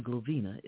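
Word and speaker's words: Glovinia: And my name, Glovina, is spelled with two I's, G-L-O-V-I-N-I-A. So it's D-R, the new website, D-R-G-L-O-V-I-N-I-A, ministries, plural, .com.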